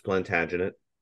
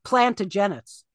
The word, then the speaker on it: Plantagenet: I also Guerrera mispronounced Plantagenet. – Yeah, she said Plantagenets. – Plantagenet, Jesus Christ.